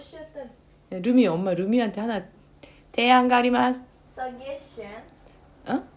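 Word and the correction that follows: suggestion: 서게션 아 루미 그 얘기하지 마 너무 발음이 너무 이상해 서게 걔 아니고 서제스션 아,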